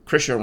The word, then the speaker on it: Krisha: Krisha rhymes with Trisha. – It's like Trisha with a K.